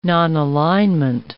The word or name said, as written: nonalignment